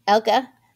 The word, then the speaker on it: Elke: E-L-K-E, Elka. And then Vogelsang, V-O-G-E-L-S-A-N-G, as far as I know.